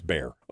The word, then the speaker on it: Bexar: It's spelled Bexar County, and I pronounced it Bexar County. But I was corrected in the chat room that it's Bear.